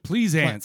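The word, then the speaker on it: Pleasence: Pleasant.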